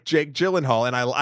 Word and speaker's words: Gyllenhaal: Um, but with Mr. Uh, Gyllenhaal, am I saying that right? Jake Gyllenhaal? Gyllenhaal? It, yeah, yeah.